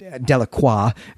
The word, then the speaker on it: Delacroix: And in fact, I think it's quite important, in fact, that the Delacroix family is in this story. That's Delacroix, though Jackson makes a note that they want the Anglo pronunciation of that as Delacroix.